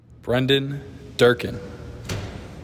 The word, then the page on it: Brendan Durkin: BREN dihn DUR kihn